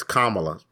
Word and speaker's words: Kamala: It's Kamala. Pronounce the sister's name.